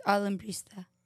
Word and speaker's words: Alambrista: We can't see how it's spelled. – Yeah, it's Alambrista. – Yeah. – Yeah. – I was saying I think you're saying it right.